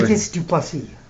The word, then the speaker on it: Dricus du Plessis: Yeah, say his name right, bro. You're the expert. – Dricus du Plessis. – Oh, there you go.